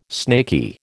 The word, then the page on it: snaky: American English